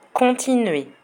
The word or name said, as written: continuer